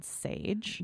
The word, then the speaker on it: Saje: To the uninitiated, it's spelled S-A-J-E, mm-hmm, Sage Wellness. Sage is really taking off.